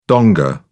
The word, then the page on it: donga: British English